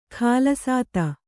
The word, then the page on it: ಕುಲಸ್ಥ: kulastha